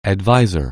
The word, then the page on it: advisor: anglais